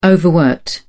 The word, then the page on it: overworked: British English